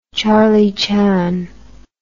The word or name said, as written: Charlie Chan